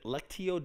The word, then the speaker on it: Lectio: Is it Lectio Divina? How is it really? – Some people say Lectio, and some people say Lectio.